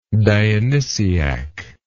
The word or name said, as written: dionysiacally